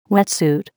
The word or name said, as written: wet suit